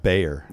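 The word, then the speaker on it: Gbayor: Did I say it right? – G silent. – Let's just keep the streak of simple names going here. – Makai Bayer. – Bayer. – Okay. – Like the aspirin.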